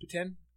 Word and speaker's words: poutine: Of course, I know a lot of people, it's pronounced poutine. If you go to Quebec, it's pronounced poutine. They're really adamant about that.